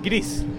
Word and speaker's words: Gris: Is it pronounced Gris or Greece? – It's pronounced actually like Greece, which okay. Is Spanish. – Okay. – It's Spanish for gray.